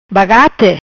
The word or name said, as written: Bagate